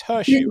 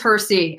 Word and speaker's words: Hersey: Do I pronounce it Hersey or Hershey? It's Hershey, right? – He's Hersey. And he used to, if he ever got a letter addressed to Mr. Hershey, he would throw it away in the garbage can right away.